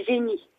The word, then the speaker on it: Jeny: Ah, mais ça s'écrit pas comme un génie, comme le, génie euh, ah. G-E-N-I-E, quoi. – Ah, c'est J-E-N-Y. – Ah, J-E-N-Y.